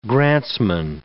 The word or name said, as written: grantsman